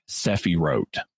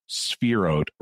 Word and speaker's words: sephirot: I should mention, for people who are just reading the word in an English text, it's going to look like sephirot. – Right. – But it's actually pronounced spherot rather than sephirot.